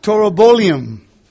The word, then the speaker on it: Taurobolium: Torobolium. Toro like bull. T-A-U. R-O. B-O. L-I-U-M.